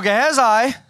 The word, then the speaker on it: Gehazi: So Gehazi, that's in Afrikaans. That's how you say it in Afrikaans and in Hebrew. So, yeah, there you go. So Gehazi, now it seems more legitimate because it sounds like it's Hebrew.